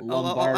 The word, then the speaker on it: Lombardozzi: Lombardozzi.